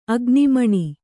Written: ಅಗ್ನಿಮಣಿ

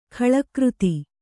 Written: ಖಳಕೃತಿ

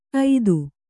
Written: ಏಱು

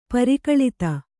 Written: ಪರಿಕಲಿತ

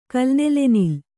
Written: ಕಲ್ನೆಲೆನಿಲ್

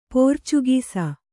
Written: ಪೋರ್ಚುಗೀಸ್

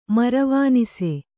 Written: ಮರವಾನಸಿ

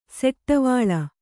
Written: ಸೆಟ್ಟವಾಳ

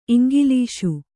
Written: ಇಂಗ್ಲೀಷು

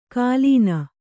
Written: ಕಾಲೀನ

